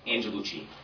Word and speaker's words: Angelucci: And did you say your last name was Gelucci? – Angelucci. A-N-G-E-L-U-C-C-I. – Okay, Gloria. A-N-G. – Angel U-C-C-I.